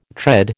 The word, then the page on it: tread: American English